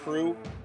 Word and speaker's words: Proulx: It's French-Canadian, and so the L and X are silent, and so it's pronounced Prue, like P-R-U-E, or the nickname for the Prudential is the Prue.